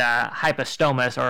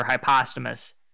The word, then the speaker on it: Hypostomus: It's a shortening of plecostomus, which formerly was a genus and has since been reabsorbed into Hypostomus or Hypostomus, depending on how you want to pronounce it. I think Hypostomus plecostomus definitely sounds better than Hypostomus plecostomus. But again, how good something sounds doesn't mean that's how you should pronounce it.